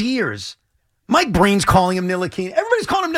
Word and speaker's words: Ntilikina: Frank Ntilikina.